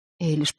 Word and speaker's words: Эйлиш: Эйлиш покачала головой. «Я не знаю». «У вас месячные?» — спросила мисс Фортини. Эйлиш снова покачала головой. «Эйлиш!» — мисс Фортини произносила ее имя странно, с ударением на втором слоге.